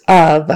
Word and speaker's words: of: That's a short U sound. Of. Even though it's spelled O-F, we say it short U-V. Of. So not F with an F sound on the end, even though it's spelled with an F.